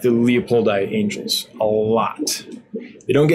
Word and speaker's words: leopoldi: With all this talk about angels, do you see quality Lelupoi? How do you say? Isn't it Leopoldi? Wait. Leopoldi, yes, sorry.